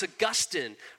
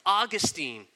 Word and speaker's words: Augustine: His name was Augustine, or maybe you've heard it pronounced Augustine.